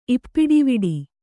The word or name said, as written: ಇಪ್ಪಿಡಿವಿಡಿ